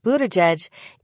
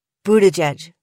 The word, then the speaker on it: Buttigieg: The trick to pronouncing the last name of presidential candidate and South Bend, Indiana mayor Pete Buttigieg is to keep your lips almost totally puckered through all three syllables.